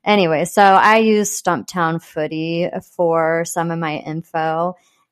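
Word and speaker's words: Stumptown Footy: Did I say that right? – Stump Town Footy. – Oh, yeah, Stump Town. Did I say Stumpy Town? – Stumpy.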